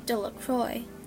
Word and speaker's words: Delacroix: Bobby and Harry and Dickie Delacroix, the villagers pronounced this name Delacroix,